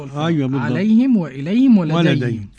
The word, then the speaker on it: عليهم: عليهم هنا بقى، عليهم أولا ضم الهاء لأن قبلها ياء دينية، لكل من حمزة رضي الله عنه ويعقوب الحضرمي. – تنطق كيف فضيلة الشيخ؟ – عليهم.